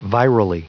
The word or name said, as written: virally